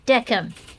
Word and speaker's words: decem: The Roman numeral is pronounced decem, not ten.